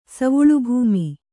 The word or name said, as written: ಸವುಳುಭೂಮಿ